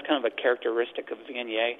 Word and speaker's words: Viognier: Ah, Viognier.